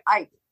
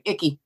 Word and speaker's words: Icke: That's funny you say it, Ike. And we say it, Icky. – No, well, that's how it's pronounced.